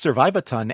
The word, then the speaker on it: Survivaton: It's funny because it's pronounced Survivaton and it is short for Survive a Ton.